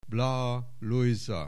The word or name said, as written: Blaha Lujza